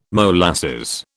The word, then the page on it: molasses: British English